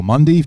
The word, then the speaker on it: Mendy: And Mendy, or Mundy, to use the French pronunciation, probably yet to really establish himself at the level too.